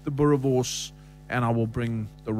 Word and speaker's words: Boerewors: Boerewors.